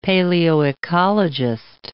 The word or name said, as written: paleoecologist